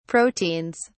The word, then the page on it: proteins: British English